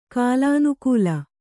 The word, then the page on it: ಕಾಲಾನುಕೂಲ: kālānukūla